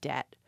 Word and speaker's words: debt: And a good example here is the word debt, that silent B. The reason behind that is that we borrow the word into English from French, spelled D-E-T-T-E.